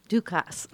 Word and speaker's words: Dukas: Dukas. I said Dukas. – I have too, but I learned the hard way that is actually the S is pronounced and his family insisted on it.